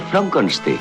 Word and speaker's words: Frankenstein: Doctor Frankenstein. Frankenstein. Se pronuncia Frankenstein.